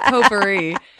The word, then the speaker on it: potpourri: I said it wrong. It's potpourri.